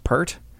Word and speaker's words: Peart: which I believe is pronounced Pert.